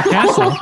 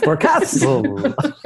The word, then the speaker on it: Forecastle: Yeah, it's awfully hot in July, and I love Forecastle, but putting the wrong emphasis on the wrong syllable. – Forecastle. Forecastle.